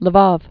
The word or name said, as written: Lvov